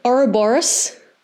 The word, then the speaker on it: Ouroboros: Ouroboros.